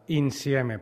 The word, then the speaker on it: Insieme: El títol, i ja que tenim un convidat que és italià, Luca Tancredi Barone, es diu Insieme. Ho he pronunciat bé o no, Luca? – Insieme, perfecte.